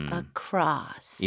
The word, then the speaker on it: across: across. 입 모양을 좀 벌려 줘야겠네요. – 네, 맞아요. 그쵸? 그리고 강세는 cross에 들어가니까 어는 짧고 약하게 across.